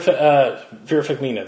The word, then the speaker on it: Farmiga: You know, so, like, I love Vera Farmiga. That's how you pronounce it.